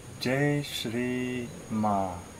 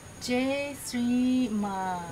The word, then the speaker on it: Jai Shri Ma: So the words for the songs is Jai Shri Ma. – Jai Shri Ma. – Yeah. And so the R sound is difficult for Chinese, so you can say R.